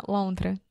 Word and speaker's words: lontra: Uh, lontra.